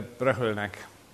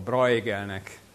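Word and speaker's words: Bruegel: A kép, amit látnak, az idősebb Peter Bruegelnek, vagy közismertebb, ám helytelen kiejtéssel a nevét mondva, Bruegelnek a híres rajza a művészről és a műértőről.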